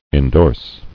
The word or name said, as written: Indorsor